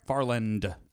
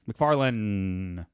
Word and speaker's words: MacFarlane: MacFarlane. – Is that the car? – Farland. – Farland. There's no D. – MacFarlane.